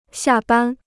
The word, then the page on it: 下班: xià bān